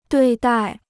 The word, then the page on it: 对待: duì dài